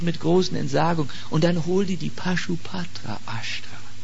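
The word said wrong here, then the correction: Pashupatastra: Das richtig gesagt. Pashupatashtra, sagt man.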